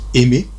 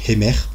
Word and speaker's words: Aimer: Donc, moi, je dis Aimé. Mais apparemment, c'est Hemer. Je devrais le prononcer en disant Hemer.